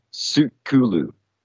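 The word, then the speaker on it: Tsul 'Kalu: Yeah, well, the T is not really pronounced. It's like a Sukulu. – That's it. Yeah, it's also, it's like a silent T.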